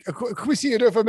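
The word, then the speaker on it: Kwesi Adofo-Mensah: Quisi Adofo? – Oh, yeah. That's right. Quisi Adofo Mensa.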